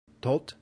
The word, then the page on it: tot: Latin